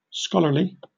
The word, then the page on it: scholarly: British English